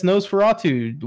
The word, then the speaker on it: Nosferatu: Yes, Nosferatu. Wes just taught me how to say it because I was like, what's NOS4A2? – Well, because they Pascal spelled it.